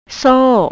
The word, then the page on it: soh: thai